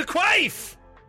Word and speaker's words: Quaife: Is it Peter? I don't know how to pronounce it. Is it Quaife? Keith? – It's Peter Quaife!